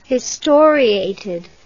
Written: historiated

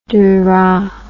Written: Duras

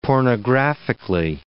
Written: pornographically

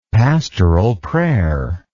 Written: pastoral prayer

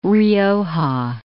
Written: Rioja

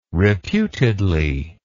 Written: reputedly

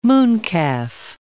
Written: mooncalf

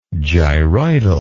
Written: gyroidal

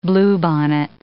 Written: bluebonnet

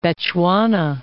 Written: Bechuana

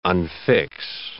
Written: unfix

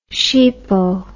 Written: sheepo

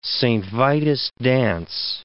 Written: Saint Vitus' dance

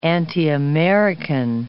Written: anti-American

